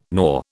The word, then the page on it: gnaw: British English